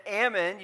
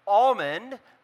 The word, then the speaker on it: almond: Just like the word salmon, you don't say the L in almond, you don't say the L, which a lot of people like to pronounce almond, but it's almond.